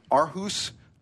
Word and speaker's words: Aarhus: Seven students recently participated in an international robotics competition held in Aarhus, Denmark.